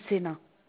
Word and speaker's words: Sena: Moi, c'est Sena. – Ça s'écrit comment? – S-E-N-A.